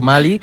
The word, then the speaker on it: Mali: O sea, se puede decir Mali o Malí, como nos venga en gana. De hecho, la RAE dice que Mali sin acento viene de la pronunciación en inglés y Malí viene de la pronunciación francesa.